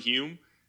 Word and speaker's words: Hulme: My last name is spelled Hulm as in Michael E. But on Instagram, it's just @Charlie Hulme.